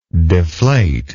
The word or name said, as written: deflator